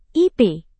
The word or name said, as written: ip